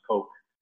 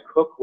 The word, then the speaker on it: Coke: And by the way, we only knew for the past 20 years that it's not pronounced Coke. So at Oxford, all my teachers say Cook, but I think older lawyers would say, hey, Coke, it'd be a bit confusing.